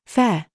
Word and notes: FIFE: British English

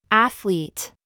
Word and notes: think: American English

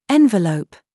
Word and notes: VERVE: British English

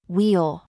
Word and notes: we: American English

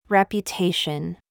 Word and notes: YOU: American English

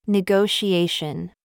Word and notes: gig: American English

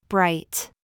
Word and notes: BOB: American English

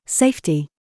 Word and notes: FIFE: British English

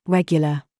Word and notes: gig: British English

